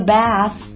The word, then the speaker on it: bath: You can play with your rubber duck in the bath. 你可以在洗盆浴的时候玩小黄鸭。 You can 可以弱读成 You can play with 你的小黄鸭 Your rubber duck In the bath 注意这个地方的 TH 在 bath 这个单词当中，要把舌尖放到两齿之间再往外吐气。